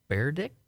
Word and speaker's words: Berdych: Quarterfinal to Berdych? – Yeah, okay.